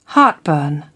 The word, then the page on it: heartburn: British English